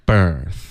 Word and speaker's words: birth: OK, birthday 也是一样，是一个 bir, 嘴巴直接从 b 抱进去一个嘴巴张开的 r, birth。